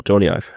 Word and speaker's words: Jony Ive: Jony Ive? – Genau, Jony Ive.